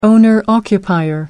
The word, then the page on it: owner-occupier: American English